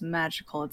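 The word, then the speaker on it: MAJKL: The team is called, I believe it's pronounced Magical. It's M-A-J-K-L, and it stands for all of their first names.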